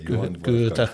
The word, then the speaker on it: Goethe: Is it Goethe? A man whose name I cannot pronounce at all. – Goethe.